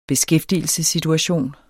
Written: beskæftigelsessituation

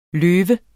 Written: løve

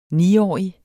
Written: niårig

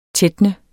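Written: TÆTNET

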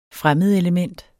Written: fremmedelement

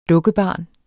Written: dukkebarn

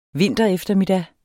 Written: vintereftermiddag

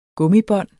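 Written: gummibånd

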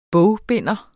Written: bogbinder

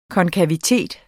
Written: konkavitet

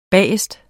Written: BAGERSTE

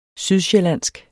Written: sydsjællandsk

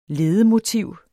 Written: LEDEMOTIVET

